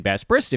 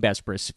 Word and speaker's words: Besbris: Before we dive into the Sixers, want to remind everybody you can follow me on Twitter at Dan Besbris. If you can spell it, you can follow me. That's the old adage for anyone with the last name Besbris.